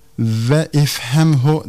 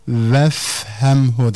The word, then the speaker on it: vefhemhu: Ve ifhemhu demeyiz. Sülasi mücerred fiillerin emri hazırlarının başındaki hemze vasıl hemzesi olduğu için söz ortasında geldiğinde okunmaz. Dolayısıyla ve ifhemhu denmez. Vefhemhu denir.